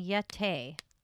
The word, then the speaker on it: Yete: the word Yeti, they think, is just a mispronunciation of the of Sherpa word Yete.